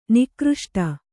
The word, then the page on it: ನಿಕೃಷ್ಟ: nikřṣta